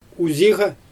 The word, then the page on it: uziɣa: Xyzyl